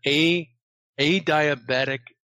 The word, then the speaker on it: adiabatic: Is in sync with this computer called an adiabatic computer. And I always want to say diabetic, but it's spelled A-D-I-A-B-A-T-I-C.